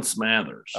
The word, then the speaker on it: Smothers: All Vol Recruiting wants to know, where does Tennessee stand with, I think it's Dalen Smothers. You're saying Smathers? – Dalen Smathers. – All right, I got it all wrong. All right, it's not how it looks like it's spelled to me.